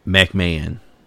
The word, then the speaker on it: McMahon: There is a Central Park Five documentary that's out that was done by Ken Burns, Sarah Burns, and David McConan. Is that how you say his name? – McMahon. – That's how you say that? – Yes.